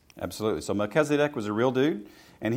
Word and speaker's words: Melchizedek: For this Melchizedek, king of Salem. – Nice pronunciation there.